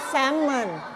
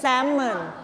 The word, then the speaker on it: Salmon: ภาษาไทยเราจะเรียกว่า Salmon แต่ภาษาอังกฤษอ่านว่า Salmon Salmon ไม่ออกเสียง L นะ Salmon